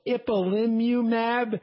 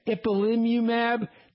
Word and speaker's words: ipilimumab: It's known chemically as, it's a little bit hard to pronounce, it's called ipilimumab. And I'm going to say it again, ipilimumab.